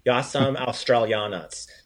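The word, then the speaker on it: Ja sam Australac: I like, so that R sound, make sure you give that R a roll. So you don't, you pronounce it like an American would. Yasam Australianas. Try to give it some more Croatian.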